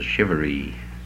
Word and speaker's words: charivari: Charivari. Charivari. Pronounced down south as a shivery.